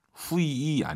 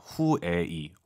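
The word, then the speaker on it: 会议: 후이이 아니죠. UI 사이에 에 넣으셔야죠. 후에이. 워낙 빠르게 발음하니까 잘 안 들릴 뿐이죠.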